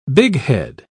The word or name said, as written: bighead